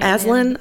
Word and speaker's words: Aislinn: A-I-S-L-I-N? – Aislinn. I have a friend. – Oh, you do? – A-I-S-L-I-N-N. – That's how you say it. Aislinn is how hers is pronounced.